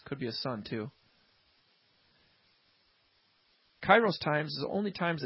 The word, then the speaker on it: kairos: And in the time of harvest, that word time, translated in the Greek, is kairos. Forgive me if I didn't pronounce it right, but I'm pretty sure I'm right on with the pronunciation of that. Kairos. K-A-I-R-O-S.